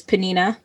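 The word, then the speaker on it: Peninnah: The pronunciation I'm going with is panina, like panini.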